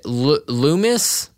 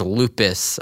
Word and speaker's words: Loomis: Loomis, we were talking about Halloween, okay. The movie. And there's a character named Loomis. I pronounce his name as Lupus, I think.